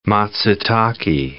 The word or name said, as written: matsutake